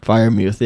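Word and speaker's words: Freiermuth: How do you say his last name? – Fryermuth? – Fryermuth, yeah.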